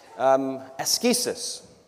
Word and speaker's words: askesis: Askesis. Askesis. It's the root from which we get the idea of asceticism, though we change it to a soft C in English.